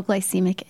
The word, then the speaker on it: glycemic: So it's a low glycemic index sugar. Did I say that right? – Yeah. – Low glycemic index sugar.